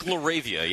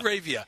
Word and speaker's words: LaRavia: Was it Laveria? – I think you've set a record for the most different pronunciations of a single player's last name. Yeah, Jake Laravia, yeah. – Laravia.